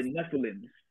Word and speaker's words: Nephilim: In the book of Genesis, we know about the Nephilim, right? I believe that's what it's pronounced. – Yes.